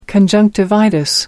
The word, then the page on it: conjunctivitis: American English